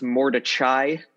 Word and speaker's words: Mordechai: I am particularly fond of their first record, Universe Smiles Upon You, and their most recent record, which I think is pronounced Mordechai. It's like Mordecai, but there's an H in there.